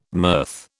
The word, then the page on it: mirth: British English